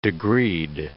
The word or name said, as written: degreed